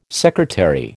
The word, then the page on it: secretary: American English